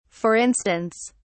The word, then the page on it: for instance: British English